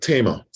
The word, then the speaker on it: Thema: You mentioned one thing, and I know that at the end of this year, you will transition over to Thema Bryant as the next APA president. – Yeah, she pronounces it Tema.